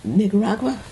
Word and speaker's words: Nicaragua: And it's been all the way to Nicaragua. Is that how you pronounce that? – Yeah.